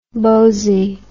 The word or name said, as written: bosie